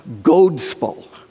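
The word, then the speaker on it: godspel: It comes from an Anglo-Saxon term that would have been pronounced godspell. Became godspell or gospel, God's story.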